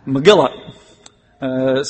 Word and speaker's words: Megillah: We would pronounce it Megaloth, M-E-G-I-L-L-O-T-H. My English pronunciation wants to call it that, but every source that I've looked at that gives a Hebrew pronunciation puts the emphasis on the second syllable. Megillah.